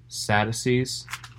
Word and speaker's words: Sadducee: Uh, by the way, that is how you say it, Sadducee.